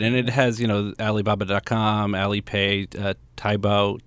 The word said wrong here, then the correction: Taobao: that's not pronounced right. How do you pronounce Taobao? – It? Yeah, Taobao. – Taobao.